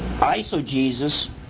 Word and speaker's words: eisegesis: Eisegesis, it's spelled Eisogesis, but it's pronounced just like Jesus, so a lot of people misspell it if they just hear it.